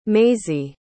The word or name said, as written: maisy